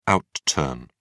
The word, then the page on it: outturn: British English